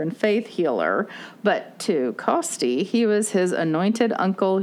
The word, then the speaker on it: Costi: Like C O S T I, Costi Hinn.